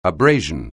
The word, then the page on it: abrasion: anglais